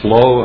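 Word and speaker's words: Loewe: Hi, I'm Curtis Lowe.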